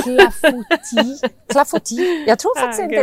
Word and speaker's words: clafoutis: Clafoutis. – Ja, men faktiskt, clafoutis.